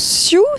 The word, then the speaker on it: SEW: On prononce SIEW, ça s'écrit S-E-W.